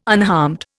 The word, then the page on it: unharmed: Indian